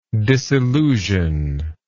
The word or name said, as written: disillusive